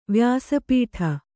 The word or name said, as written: ವ್ಯಾಸಪೀಠ